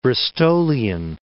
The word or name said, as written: Bristolian